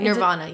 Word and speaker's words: Nirvana: Nirvana, yeah.